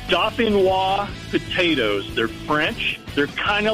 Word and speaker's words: Dauphinois: All right, so pronounce that for me one more time so I don't sound like a complete idiot when I order them. – No problem. It's, like I said, it's French. It's Dauphinois.